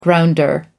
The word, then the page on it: grounder: American English